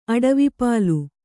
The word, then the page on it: ಅಡವಿಪಾಲು: aḍvipālu